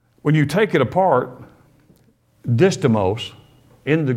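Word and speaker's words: distomos: But it's D-I-S-T-O-M-O-S, distimos. And it's pronounced many different ways, but I don't care how you pronounce it. Do what you want to with it. Distimos.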